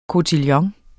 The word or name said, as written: kotillon